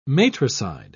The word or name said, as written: matricide